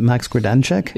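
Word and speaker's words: Max Grodénchik: I'm bad at pronouncing his last name. Is it Max Gradencheck? – Yeah, very good. Well done.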